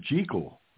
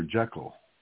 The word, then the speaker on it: Jekyll: It was Dr. Jekyll, not Dr. Jekyll. – True. – We smoothed it out, but they made it a long E.